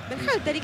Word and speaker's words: Berhalter: Berhalter.